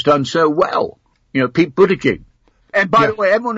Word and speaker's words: Buttigieg: That's an easy name. It's Buttigieg. – Buttigieg. – Buttigieg. It's very easy.